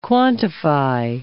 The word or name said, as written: quantify